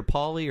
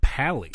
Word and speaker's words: Pally: And is his last name pronounced Paley or Pauly or? – No idea. – I think it's pronounced Pally.